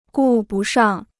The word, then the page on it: 顾不上: gù bu shàng